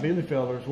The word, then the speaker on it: Bielefelder: But cool thing about interesting thing about Bielefelders, we had them for a week or so before we knew how to pronounce the name. – Oh, you know, or longer. – And it's Bielefelder.